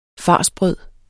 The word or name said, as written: farsbrød